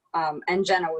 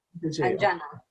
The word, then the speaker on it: Anyana: Anjana with a Y, pronounced like a J. – How do you spell your name? – A-N-Y-A-N-A. So the Y is pronounced like a J, Anjana.